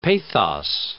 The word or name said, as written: pathos